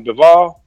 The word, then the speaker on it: Bivol: That's how you pronounce his name, Bivol? – Yeah.